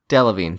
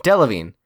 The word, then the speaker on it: Delevingne: Delevingne. – Delevingne.